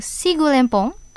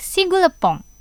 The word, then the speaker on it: Sigulempong: Traditionnellement, les habitants de Sumatra du Nord prononcent le mot « Sigulempong » avec « Sigulepong ».